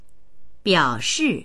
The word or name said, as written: biao3shi4